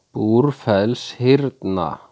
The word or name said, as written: Búrfellshyrna